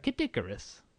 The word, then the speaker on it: Kid Icarus: Kid Icarus.